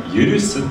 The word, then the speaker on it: 許す: これね、許すって読みます。